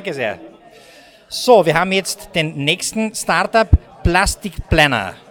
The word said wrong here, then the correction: Plastikpreneur: Habe ich das richtig ausgesprochen? Sonst werdet ihr mich korrigieren. Hallo, Servus. Servus, hallo. Was macht ihr genau? – Also, da mein Kollege Boris, Raffaella und wir machen Plastikpreneur.